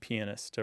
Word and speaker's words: pianist: So you wouldn't pronounce the O in piano when you said pianist or the O in cello when you said cellist.